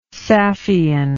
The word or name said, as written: saffian